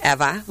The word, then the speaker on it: Eva: My name is spelled E-V-A, Eva. Okay, pronounced Eva.